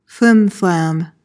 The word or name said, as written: flimflam